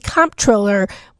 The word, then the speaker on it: comptroller: No, but the correct pronunciation is controller, but typically you see the spelling of controller, C-O-N-T-R-O, in the private sector. And you'll see comptroller more common in the public sector. – I never knew that. – They're basically pronounced the same way.